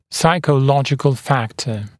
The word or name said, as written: psychological factor